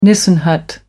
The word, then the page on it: nissen hut: American English